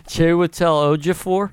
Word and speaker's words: Chiwetel Ejiofor: Tuelto Ejiofor. – Chiwetel Ejiofor? – Yes.